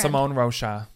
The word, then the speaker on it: Simone Rocha: I'm going to butcher this. Simone Rocha? How do you say the name of this brand? – Simone Rocha.